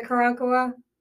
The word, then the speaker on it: Karankawa: I love the Karankawa.